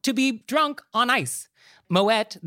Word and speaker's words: Moët: So you do say the T. It's Moët. He was Dutch. He wasn't French. So the correct pronunciation is Moët.